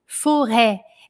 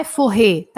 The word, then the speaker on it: forêt: Atenção à escrita da palavra floresta e à pronúncia, forêt, é aberto, forêt, forêt, forêt, forêt. Escreve forretes com acento circunflexo no e, forêt, não é forré, tá? É forré, forré, forré, tá bom?